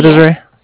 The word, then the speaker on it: Desiree: Okay, and how do you spell it, Desiree? Yeah, go ahead. – D-E-S-I-R-E-E.